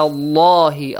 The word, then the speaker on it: Allah: Ala Allahi. Allah is pronounced with a light or heavy sound. Which heavy. – One? Heavy sound.